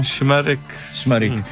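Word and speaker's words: Şımarık: como é o caso do enorme êxito Simarik, Como é que é a pronúncia correta deste tema? – Shmarik.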